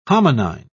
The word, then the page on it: hominine: British English